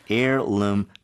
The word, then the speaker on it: heirloom: 그냥 piece가 아니라 heirloom piece가 나왔어요. – Okay. Now you don't hear the H, but it's spelled H-E-I-R. L-O-O-M. Heirloom.